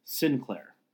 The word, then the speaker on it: St. Clair: the pronunciation of it that I've, that me and the other park historians of Saratoga have figured out, seems to be more like pronounced Sinclair.